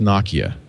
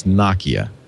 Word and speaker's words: Nokia: By the way, we now know it's pronounced Nokia. Steve Ballmer has confirmed it's Nokia.